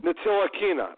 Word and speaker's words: Ntilikina: Ntilikina, Frank Ntilikina, the kid from France who we just spent a bunch of time on earlier with BJ. Frankie Ntilikina, the guard out of France.